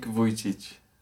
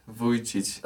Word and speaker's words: Vujicic: Nick Vujicic. Say again the Vici Vici part. Nick Vujicic.